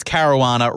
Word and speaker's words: Caruana: Yes, it's Caruana, rhymes with marijuana.